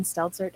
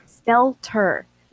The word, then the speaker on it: Stelter: Did you see the interview with Brian Stelzer? I always say his name incorrectly. – Stelter.